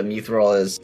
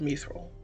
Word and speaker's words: Mithril: That is how it's pronounced. It's pronounced Mithril.